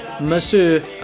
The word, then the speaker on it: monsieur: And now the young monsieur and madame have rung the chapel bell. C'est la vie, say the old folks. It goes to show you never can tell. 这两句里边，第一个需要注意的地方是 and now，and 的不发音读成 and now。两个法语词 monsieur and madame，monsieur，madame，monsieur，madame。中间的 and 依旧第一不发音，直接读成 and。 Monsieur and Madame run the chapel bell。